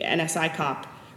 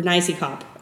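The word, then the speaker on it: NSICOP: So making sure that the review bodies, and actually I should include in that the NSI COP, or NICE COP, as we've learned to pronounce it.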